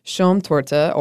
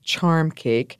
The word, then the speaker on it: Schaumtorte: Schaumtorte, or foam cake, which was often mispronounced as charm cake, and Baisertorte, or kiss cake.